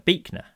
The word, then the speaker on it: Buechner: Then I was thinking about what Nancy's, that quote from Frederick Buechner, it's pronounced. – Buechner, is it? – Yeah, apparently.